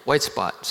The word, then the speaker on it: White Spot: Did I say that right? Not White Spot. Yeah, White Spot.